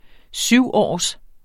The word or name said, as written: syvårs